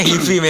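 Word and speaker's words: HIVI!: Tulisannya hifi memang, cuman kan bacanya hi-fi. Iya, dibacanya hi-fi.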